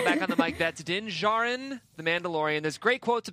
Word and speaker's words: Djarin: Sorry, I was just going to say it's pronounced Din Jarin. Oh, Jarin. Jarin.